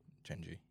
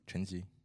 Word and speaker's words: Chenji: So how would you say your name, like your with that full accent? – Chen Ji. – Say that again. – Chen Ji. – Chen Ji. – Ji. I don't think most people can pronounce that.